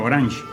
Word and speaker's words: Orange: Orange, que se pronuncia Orange, es France Telecom, Sociedad Anónima Unipersonal.